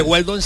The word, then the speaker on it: Weldon: Pues son la página web de Hueldón, te apuntas y ahí ya que Weldon se escribe W-E-L-D-O-N.